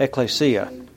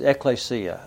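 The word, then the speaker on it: Ecclesia (ἐκκλησία): Ecclesia. – I was going to spell it. – Yeah. It's ecclesia.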